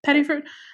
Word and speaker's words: Pettiford: Like, we got to talk about Miss Valerie Pettiford. Am I saying that right? Am I pronouncing her last name right? – Pettiford.